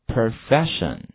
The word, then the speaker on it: profession: 好，它的重音呢其实是在 F 这个地方，所以说的话它的读音一定是这样的，跟我读一下 profession。好，用声调读一遍 profession profession。 OK，还有这个 O 的发音，大家不要见到 O 就发 O，这个地方 O 的发音在这个词里面是 E。好，跟我读一遍 profession good。